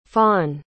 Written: fawne

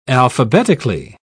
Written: alphabetically

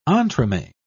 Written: entremets

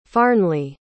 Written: farnly

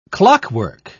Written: clockwork

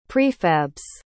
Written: prefabs

